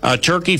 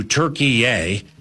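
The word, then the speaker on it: Türkiye: Turkey formally changed its name to Turkey A. They say Turkey A. There are other pronunciations that go Turkey, yeah, but it's not turkey. It's not like what you eat at Thanksgiving. It's not like three strikes in a row at a bowling alley.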